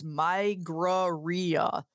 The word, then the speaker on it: Migraria: Let's get this straight. How it's pronounced. It's migraria. Two words together. Migraria. Migraine. – Okay. – Spliced with diarrhea.